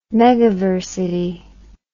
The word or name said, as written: megaversity